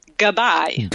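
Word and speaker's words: Gabbay: Gabay winning, or how do we pronounce it? Is it Gabay? – Gabay.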